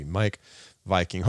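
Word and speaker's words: Meik Wiking: His name is spelled, by the way, M-E-I-K space W-I-K-I-N-G. Mike Viking.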